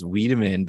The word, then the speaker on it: Wiedemann: I like Wildman better, actually. My name's Wiedemann, but Wildman.